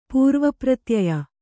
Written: ಪೂರ್ವಪ್ರತ್ಯಯ

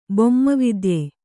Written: ಬೊಮ್ಮವಿದ್ಯೆ

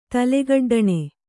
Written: ತಲೆಗಡ್ಡಣೆ

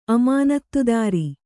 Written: ಅಮಾನತ್ತುದಾರ

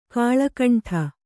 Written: ಕಳ್ಳಕಿಂಡಿ

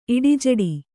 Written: ಇಡಿಜಡಿ

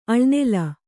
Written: ಅಳ್ನೆಲ

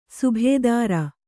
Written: ಸುಬೇದಾರ

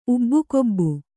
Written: ಉಬ್ಬುಗೊಬ್ಬು

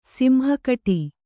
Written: ಸಿಂಹಕಟಿ

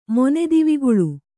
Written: ಮೊನೆದಿವಿಗುಳು

